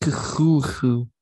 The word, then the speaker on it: Cthulhu: Merlinoff, Cthulhu, Ryle, Wachnag, Ftagen. Okay, my sorry, I got the stuff out of my throat. Ready to begin. – It's pronounced Cthulhu.